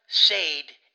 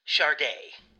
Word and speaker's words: Sade: It's not Zaid. It's pronounced Sharde. – S-A-D-E is Sharde?